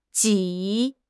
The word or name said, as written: ji3